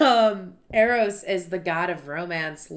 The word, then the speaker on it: Eros: Eros. Am I saying that right? E R O S. – Eros. Eros. Eros. I would say Eros. Eros. That's Eros. like the Cupid god of whatever.